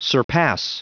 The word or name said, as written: surpass